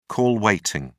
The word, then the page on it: call waiting: British English